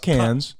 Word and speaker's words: Cannes: It's pronounced Cannes.